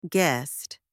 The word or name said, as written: guest